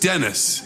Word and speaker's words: Dennis: I said, it's pronounced Dennis. Okay. Get it right.